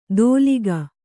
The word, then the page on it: ದೋಲಿಗ: kannada